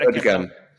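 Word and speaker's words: Erdoğan: Erdogan? Damn it. – Erdogan.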